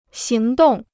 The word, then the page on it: 行动: xíng dòng